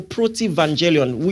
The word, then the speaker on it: protevangelium: There is something theologians call protevangelion, which is spelled P-R-O-T-E-V-A-N-G-E-L-I-U-M. Protevangelion.